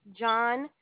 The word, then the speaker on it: Johntasia: Gentesia. It's spelled. – Excuse me? Dawn? – Gentesia. It's spelled J-O-H-N-T-A-S-I-A.